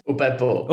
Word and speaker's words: Upepo: Am I saying it right, guys? – Upepo. – Upepo.